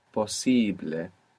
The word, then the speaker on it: posible: We just have to pronounce it syllable by syllable and put the accent second from the end. So possible becomes posible.